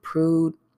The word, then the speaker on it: Prude: So, if you do not know about the Daniel Prue case, I'm always saying somebody's last name wrong, but I believe you say it's pronounced Daniel Prude.